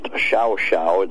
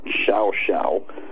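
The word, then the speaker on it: Llao Llao: That, I believe, was 1960, July of 1960, because it was hanging in the lobby of that ultra-high-buck hotel, pronounced Xiao Xiao. It's LLAO-LLAO, but it's pronounced Xiao Xiao.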